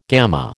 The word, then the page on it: gamma: American English